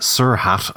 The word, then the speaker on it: sr.ht: Yeah, um, SRHT. I have no idea how he intends it to be pronounced. – Sir Hat, apparently, but that just sounds silly.